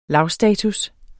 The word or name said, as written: lavstatus